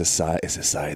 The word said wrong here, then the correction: acai: the one? Is it the chosen one? Am I saying it right? – Acai, yeah.